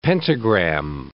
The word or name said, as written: pentagram